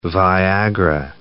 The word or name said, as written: Viagra